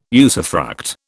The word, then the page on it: usufruct: British English